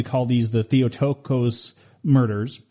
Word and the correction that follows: Theotokos: It's pronounced Theotokos. – Oh, Theotokos. I apologize. – No, it's not. No, just it's a hard word. It's a Greek word.